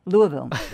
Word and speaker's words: Louisville: Louisville. – Yep. That's where I was. – Thank you. Now I don't have to say it again. Louisville.